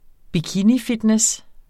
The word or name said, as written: bikinifitness